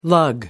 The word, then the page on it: lug: American English